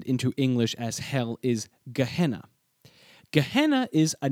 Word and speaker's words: Gehenna: This word Gehenna is fascinating.